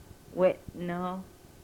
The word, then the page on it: wenoh: Yurok